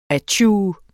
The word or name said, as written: atju